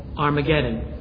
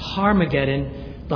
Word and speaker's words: Armageddon: Armageddon. And I told you repeatedly that you could pronounce that Har, like with an H, Harmageddon.